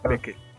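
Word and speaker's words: Beckeles: La pronunciación es Bekele.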